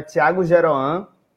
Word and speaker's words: Tiago Geroan: Como o Camis e, e Manina já falaram, meu nome é Tiago Geroan né? É, se escreve mais difícil do que se fala.